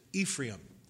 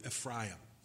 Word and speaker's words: Ephraim: I know most Christian preachers express or pronounce it Ephraim, but being from a Jewish background, it's accurately pronounced from the Hebrew, Ephraim.